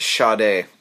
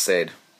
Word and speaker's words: Sade: It's Sade. It's not Sade.